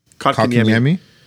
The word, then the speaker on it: Kotkaniemi: Kotkaniemi. – Kotkaniemi.